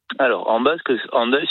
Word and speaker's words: Hendaye: Et d'ailleurs, Andaille s'écrit aujourd'hui H-E-N, donc peut-être qu'en français on dirait plus facilement Andaille. – Ah oui. – Mais c'est bien Andaille qu'on dit. On a bien conservé le son ou la prononciation qui était celle de l'orthographe du XVIe siècle.